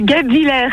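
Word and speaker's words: Guebwiller: On y est presque, la prononciation est pas ouf. – Oh là là là là là là là. Vous avez raison. Je sais pas parler. Oh là, comment qu'on prononce? – C'est Goupvillers.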